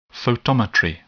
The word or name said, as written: photometry